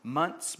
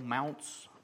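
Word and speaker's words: Mounce: Bill, I'm going to guess his name is pronounced Munce, but it's M-O-U-N-C-E. Bill Mounts. Mounts just don't sound right.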